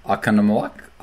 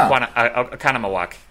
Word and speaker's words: Oconomowoc: Oconomowoc? – Oconomowoc. Yeah, that was actually very close. Oh. Oconomowoc. Yep. – Oconomowoc.